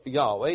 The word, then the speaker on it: Yahweh: They prefer to use the word pronunciation Yahweh.